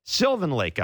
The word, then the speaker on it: Sylvan Lake: Why did I pronounce it like Sylvain, as in Sylvain Lefebvre? Sylvan Lake, Alberta.